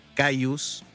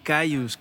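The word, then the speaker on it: Kyuss: Sí, Cayus, que al principio la verdad no sabía cómo se pronunciaba, si era Kius o Cayus, o qué, que es K-Y-U-S.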